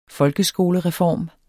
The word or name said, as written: folkeskolereform